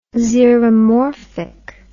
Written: xeromorphic

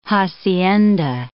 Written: hacienda

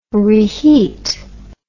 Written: reheat

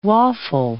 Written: waffle

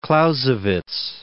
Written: Clausewitz

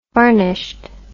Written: varnished

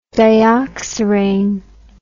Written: dioxirane

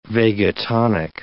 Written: vagotonic

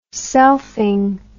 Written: selfing

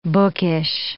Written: bookish

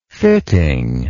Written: fittingness

